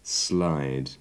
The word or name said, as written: slide